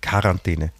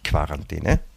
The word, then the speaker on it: Quarantäne: Es heißt Quarantäne, nicht Quarantäne. Ja? Das ist Quarantäne.